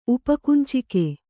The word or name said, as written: ಉಪಕುಂಚಿಕೆ